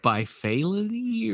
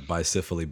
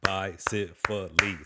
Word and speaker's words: bicephaly: Is it bifality or, you know, And I was going to ask you, I was like, hey, how do you pronounce it? – Yeah. Bicephaly, baby. How to break down those syllables, man. You know, like that, you know, back in kindergarten, do the syllable clap.